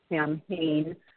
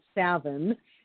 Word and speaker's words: Samhain: I have Keith Ferrario is a horror novelist, and he had four books out under his he pronounces it Samhain. I imagine they do, too, and that's very distressing to me because it's a Gaelic word, and it should be pronounced Savin. The M-H makes a sound. And I know this because my daughter's name is Kiva, C-A-O-I-M-H-E.